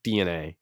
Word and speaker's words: DeNA: They're called, it's spelled D-E-N-A, but it's, so a lot of people have been pronouncing it Dina, but it's actually pronounced DNA.